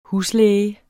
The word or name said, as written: huslæge